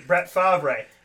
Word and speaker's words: Favre: And I said, Mom, that's how you spell Favre. – Yeah. F A V R E. Brett Favre. Well, this, folks, is how you say it.